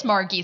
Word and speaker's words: Margie: Most people call her Margie, and it is Margie. So you got the hard G.